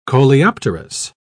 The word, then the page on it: coleopterous: British English